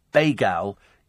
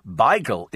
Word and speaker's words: beigel: Uh, pronounced Bagal is oop north and very American. Beigel is mainly north and east London. And also the correct way, says Peter.